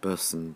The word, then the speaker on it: Boatswain: You know, it's written Boatswain, but I think pronounced Bosson, because the curator at Newstead Abbey corrected me. Thank goodness she did.